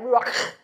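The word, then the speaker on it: ruach (רוּחַ): You've got to say it like that, ruach.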